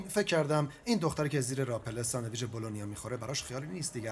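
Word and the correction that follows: bologna: خب اول این که بلونی تلفظشه، دوم این فلونیه، من یه گیاهخوارم، سوم فلونی خودم رو بیشتر دوست دارم.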